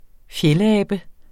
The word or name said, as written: fjeldabe